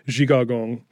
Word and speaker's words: Zhigagong: So that day, a coalition of groups came together in Grant Park for a protest that they titled Decolonize Zhigagong, which is the original native pronunciation of the word that we now call Chicago.